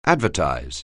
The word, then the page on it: advertise: anglais